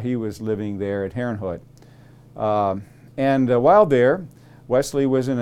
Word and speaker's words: Herrnhut: Herrnhut is spelled H-E-R-R-N-H-U-T.